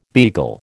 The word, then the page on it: beagle: American English